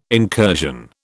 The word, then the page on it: incursion: British English